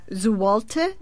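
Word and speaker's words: złoty: The Poland, I believe, still uses the Zloty or something of that sort. – Yes, it's pronounced... Zwolte, but yes, yeah. Spelled like Zloty.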